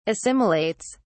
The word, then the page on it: assimilates: British English